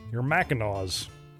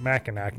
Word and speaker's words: Mackinaw: You know, to break out your woolies and your fuzzy slippers and your Mackinaws. Or is it Mackinac? No, it's Mackinaw. That's how you say it.